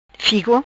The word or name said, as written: Figo